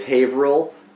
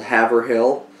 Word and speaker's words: Haverhill: And he mispronounces them both. He says Haverhill is called Haverhill.